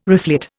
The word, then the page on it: reflet: Indian